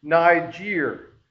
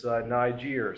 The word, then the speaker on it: Niger: And so we actually looked up a Latin pronunciation of this, and this is like phonetic, whereas Niger is how you would pronounce this Latin phrase for dark complexion is Niger.